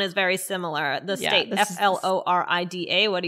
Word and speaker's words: Florida: I say Florida. I pronounce O, but a lot of people who I think it's a Jersey thing too would say what? – Florida. – Yeah. Yeah. That sounds very New Jersey to me.